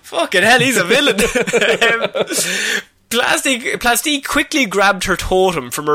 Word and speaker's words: Plastique: Plastic, I'm sorry. – Oh, for God's sake. No, it's Plastique. Plastic.